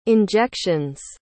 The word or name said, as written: injections